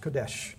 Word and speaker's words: Qodesh: The Old Testament word would be Kodesh. And I think it's spelled something like Q-O-D-E-S-H, Kodesh. And that's, I hope I'm pronouncing it right. I'm not a Hebrew scholar, but anybody who's got a dictionary can look it up. Thank you. Somebody said that's right.